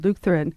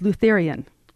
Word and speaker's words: Lutheran: For example, Lutheran was Lutherian. So you have a generation of students who say Lutherian.